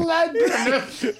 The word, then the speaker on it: Labyrinth: Say that again. – From Lebanon. I think I've said it wrong. – Labyrinth. – What is it? – Labyrinth. – Labyrinth. All right.